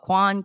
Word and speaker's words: Kwan: So my last name is spelled Kwan, K-W-A-N.